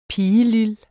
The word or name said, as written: pigelil